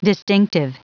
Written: distinctive

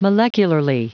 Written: molecularly

